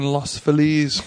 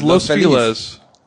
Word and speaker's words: Los Feliz: Everyone says Los Feliz. – I say Los Feliz. – Everyone says Los Feliz. The correct way of saying it is Los Feliz.